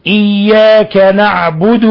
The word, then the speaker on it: إياك: ومن الأخطاء تفخيم الكاف عند الوصل يعني إياك إياك، لا إياك، لأن الكاف حرف استفال، حرف مرقق ليس من حروف التفخيم، يعني يتسفى ينزل اللسان تحت عند النطق به، فلا تقل إياك أو تقرب من القاف إياك، لا، وإنما إياك نعبد وإياك نستعين. فإذا